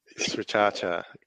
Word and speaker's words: Sriracha: Sriracha, correct.